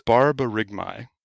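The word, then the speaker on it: borborygmi: And borborygmai. Did I say that right? – Not quite. – Say it for me. – It's hard to do it while smiling. It's barbarygmai. – Barbarygmai.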